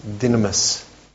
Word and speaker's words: dynamis: The dynamis, spelled like dunamis, but it's pronounced dynamis, power.